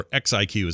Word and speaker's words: XIQ: Recently, we've been using Notecast by EXIQ, or XIQ is how you pronounce it, which is available on both iOS and Android.